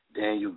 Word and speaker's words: Danyeil: Y'all can find me on Facebook, Daniel Green. My name is spelled D-A-N-Y-E-I-L, green like the color.